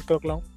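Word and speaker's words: craquelin: Y tomé el nombre de Crackland, la, pronunciación en francés es Crackland, eh, es, una pequeña cobertura que lleva la tradicional pasta choux.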